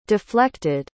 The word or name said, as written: deflected